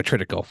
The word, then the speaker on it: Triticale: They use malts from Sugar Creek, Vienna, Two Row, Triticale, Spelt, and Shit. And then they use a German lager for their yeast, which makes sense. – What did you say, Triticale? – Yeah. There you go, Triticale.